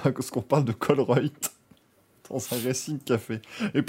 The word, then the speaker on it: Colruyt: le magasin, c'est C-O-L-R-U-Y-T. J'ai essayé, j'ai pas, pas réussi. Et du coup, donc ça, ça, se prononce Colruyt.